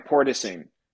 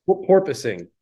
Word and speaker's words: porpoising: a portaging. So like you're like Portaging. – pretending you're a dolphin and you're going from place to place. – Porpoising.